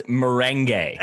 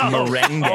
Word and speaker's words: merengue: And also, I just want to clarify, it's pronounced merengue. It's merengue.